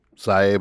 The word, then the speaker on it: Psy: Cy Abelman. – But it's spelled P-S-Y.